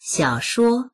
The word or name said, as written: xiao3shuo1